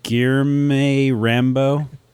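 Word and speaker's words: Guilherme Rambo: Guillerme Rambo. – Whatever. You had it right. – Guillerme Rambo. – Guillerme Rambo.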